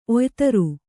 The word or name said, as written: ಒಯ್ತರು